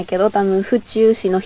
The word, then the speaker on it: 府中: 府中のアクセントは宇宙じゃなくて地球の方。